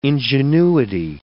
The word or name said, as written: ingenuity